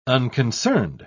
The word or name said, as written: unconcerned